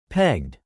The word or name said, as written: pegged